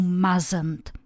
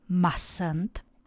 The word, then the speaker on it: mustn't: Jeden můj student tuhle vazbu pořád vyslovoval špatně a říkal you mustn't místo mustn't. A když jsem ho na to upozornila, přiznal se mně, že má svoji vlastní mnemotechnickou pomůcku. A to, že si vždycky říká mazej, mazej od toho, you mustn't, nesmíš. Tak to prosím vás takhle nevyslovujte a říkejte správně mustn't, ale třeba vám ta jeho pomůcka taky trochu pomůže.